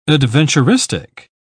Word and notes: adventuristic: British English

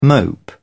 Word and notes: mope: British English

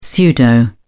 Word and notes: pseudo: British English

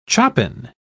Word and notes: chopin: British English

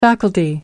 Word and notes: faculty: American English

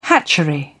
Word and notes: hatchery: British English

